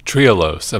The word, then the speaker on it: Trehalose: Trehalose, am I pronouncing this right? – Yeah, you did, yeah.